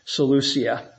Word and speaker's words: Seleucia: Seleucia, yeah.